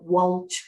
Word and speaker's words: Walsh: So you've pronounced my name very well, Walsh, which is the Irish part, but my full name is actually Walsh Zitzelsberger.